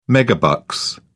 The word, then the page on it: megabucks: British English